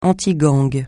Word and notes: antigang: francais

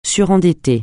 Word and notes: surendetté: French

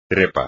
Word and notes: trepa: espagnol

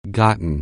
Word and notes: get: anglais

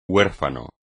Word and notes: huérfano: espagnol

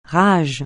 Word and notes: rage: francais

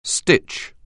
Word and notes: stitch: anglais

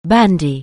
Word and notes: bandy: anglais

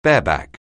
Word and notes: bareback: anglais

